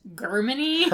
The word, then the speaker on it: Jermyny: It's spelled J-E-R-M-Y-N-Y. – Except it's pronounced Germany.